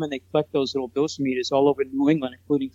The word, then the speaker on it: dosimeters: I think they call them dosimeters, is the way I heard it pronounced.